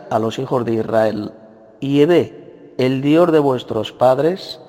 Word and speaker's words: Yahvé: Y es Yahvé, que es la pronunciación más correcta en hebreo arcaico.